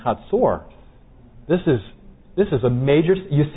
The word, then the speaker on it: Hatzor: I know some of you have pronounced it Hazor all these years, and so I'm pronouncing it a little differently here.